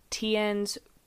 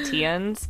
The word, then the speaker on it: Theuns: So I'm going to call him Tien's, but... – okay. – That also, I'm pretty sure that's how you say it. That's how they said it in the documentary, but it's spelled T-H-E-U-N-S.